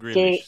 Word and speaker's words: Grealish: Eh, oigan, estaba leyendo este artículo sobre el jugador de Manchester City, en Greenwich, ¿verdad? es como se pronuncia. Jack Grealish, sí.